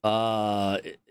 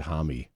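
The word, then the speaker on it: Homme: And according to a texter in the 6 o'clock hour, it's pronounced homie. Or Home? – I think he said Hami.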